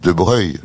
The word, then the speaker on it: Broglie: les gens de famille disent De Breuil.